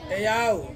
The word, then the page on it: au: Ansus